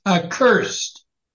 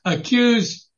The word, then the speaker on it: accursed: And the word is the last line in verse 8, the last word, which is accursed. – 所以最后一个词在里面呢是 accursed. – Accursed, that's right. So you pronounced it correctly at the end. The earlier word you used was accused, but this is accursed. There's a difference.